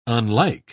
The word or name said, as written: unlike